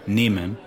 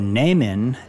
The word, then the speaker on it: nehmen: L-E-H-R-E-R. – Good. – So nehmen, N-E-H-M-E-N, meaning to take, has the silent H. – It does. – And that makes the sound longer. Nehmen.